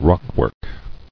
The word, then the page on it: Rockwork: rock·work